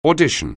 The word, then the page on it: audition: anglais